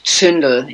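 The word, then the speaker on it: Zündel: So his name is Jürgen Neumann, and he was the right-hand man for Ernst Zundel, or Ernst Zundel, if you pronounce it in German, for several decades.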